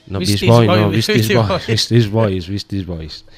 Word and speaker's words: Beastie Boys: Beastie Boys. Que mezcla distintos estilos. No olvidemos que todos los temas ni son iguales ni nada. – La pronunciación deberíamos mejorarla.